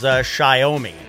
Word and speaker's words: Xiaomi: There's a company called, I believe the pronunciation is Xiaomi. X-I-A-O-M-I.